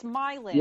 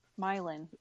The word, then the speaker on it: Milan: Oh, it's Milan. – Yes. – They say Milan, Ohio. Yeah. It's really strange. – Like the sheath around your brainstem? – Milan. Yeah. Instead of Milan.